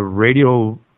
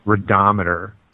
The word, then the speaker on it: radiometer: On your window ledge, you have a die-cast Jeep Wrangler, a radiometer. I don't even know what that is. I'll call it a radio meter, knowing that that is not how you pronounce it. – Radometer.